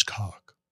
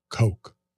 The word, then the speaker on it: Koch: JL posted a video where Donald Trump was mispronouncing the Koch brothers' name as cock. And uh, person on YouTube commented, it's actually pronounced Coke.